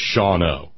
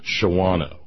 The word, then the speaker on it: Shawano: The name of the place in Wisconsin is Shawano, not Shawano. Too many vowels. Shawano. Shawano, Wisconsin. Okay.